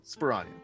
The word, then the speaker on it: Sparagna: He says it's like lasagna, but okay. Phil Sparagna.